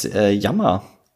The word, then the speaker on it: Yammer: Ja, ich habe aber mit Natives gesprochen, die meinten, dass es Yammer richtig ausgesprochen ist.